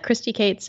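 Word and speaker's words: Kristi Kates: It's at Christy Cates, and my name is spelled KRISTIKATES.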